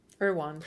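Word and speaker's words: Erdoğan: Erdogan.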